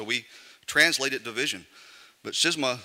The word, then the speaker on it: schisma: Now, this word that Paul uses, that we translate division, schisma, or sometimes pronounced schisma in Greek, means something a little bit different, unfortunately, than how we translate it.